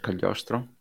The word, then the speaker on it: Cagliostro: Am I pronouncing it correctly? – Yeah, I think indeed it is Castle of Cagliostro.